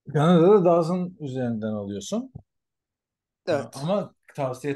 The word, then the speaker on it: DAZN: DAZN nasıl okunuyor bilmiyorum. – Aa, e, Dazın diyorlar, Dazon diyorlar. Dazon. – Dazon işte, diyor.